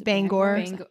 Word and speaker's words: Bangor: How do you pronounce it? – Bangor.